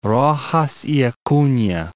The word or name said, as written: Roxas y Acuna